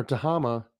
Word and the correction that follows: Tamaha: I want to say it right. Tamaha. I had my emphasis on the wrong syllable. Tamaha.